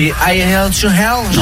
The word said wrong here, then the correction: Highway to Hell: Non mais I Hail to Hell. Non mais n'importe quoi. – Voilà, Highway to Hell, là, l'autoroute vers l'enfer.